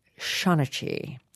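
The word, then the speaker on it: seanchaí: The word is S-E-A-N-C-H-A-I. So it looks like it says Sean Chai, but it is apparently pronounced something like Shaunachie.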